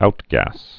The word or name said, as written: outgas